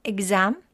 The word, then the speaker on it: exam: Exam 이 X 발음 X 발음 생각보다 우리가 알고 있는 것과는 약간 다른데요. exam, exam, exam 이렇게 발음해 주세요.